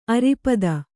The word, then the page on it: ಅರೆಪಾದ: Kannada